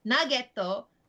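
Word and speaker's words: nageto (ナゲット): On a side note, the Japanese pronunciation of nugget, nageto, sounds like the Japanese word for hit,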